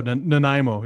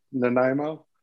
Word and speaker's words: Nanaimo: Getting back to the flight to Nanaimo, right? Is that how you pronounce it? – Oh, Nanaimo, yeah. – Nanaimo.